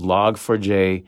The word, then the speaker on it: Log4j: I found out how to pronounce the name of this thing. It's Log4j. Because Log Forge would totally make sense because you forge logs with the software.